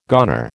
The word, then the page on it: goner: American English